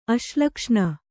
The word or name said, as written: ಅಶ್ಲಕ್ಷ್ಣ